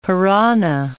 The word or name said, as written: piranha